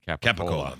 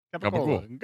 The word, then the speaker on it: capicola: Yeah, it's Capicola. – That's right. – Oh. – So the Italians just can't say Capicola. – That's how they say it. – Gabagool.